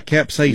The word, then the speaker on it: capsaicin: I left a message because I didn't understand the capsaicin or however you pronounce it. – Yeah, capsaicin.